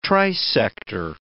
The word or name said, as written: trisector